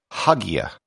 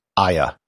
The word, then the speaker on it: Hagia: If you had no background and were just a standard English speaker, you might pronounce it Hagia. However, that has never really been how it's been pronounced. There's also Hagia, Hagia with the H silent. The standard pronunciation and what I'll be using in this episode is Aya. This would be as if it were spelled A-Y-A, which is actually how it's spelled in Turkish.